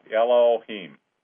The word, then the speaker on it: Elohim: So Elohim, I think is how you really pronounce it. Like yellow, Elohim.